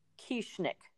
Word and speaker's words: Kieschnick: My first guest, it's a husband-wife team, and it is Weston and Molly Kieschnick.